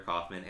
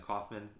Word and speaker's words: Coffman: You can find me on Instagram at Harrison Alexander Kaufman and on Twitter at Harrison Kaufman. That's spelled C-O-F-F-M-A-N.